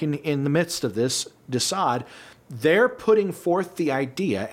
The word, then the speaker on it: de Sade: Marquis de Sade.